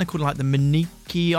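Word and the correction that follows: Minquiers: Islands. – It's pronounced the Minkies.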